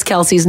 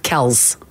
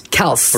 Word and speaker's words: Kelce: It's not Travis and Jason Kelce. at all. Do you want to know how you actually pronounce their name? – Is it like Kelchi? – It's not Kelchi. It is, in fact, Kells. – Kells? – Kells. – With an S? – Yep. With an S sound at the end, not a Z.